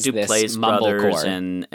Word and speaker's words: Duplass: Uh, my understanding was that it was sort of to describe films that like the Duplass brothers, Duplass brothers, I forget how you say it, Duplass,